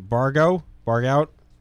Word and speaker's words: Barghout: I'm going to pronounce this horribly again. Barghout. Close? – That's perfect.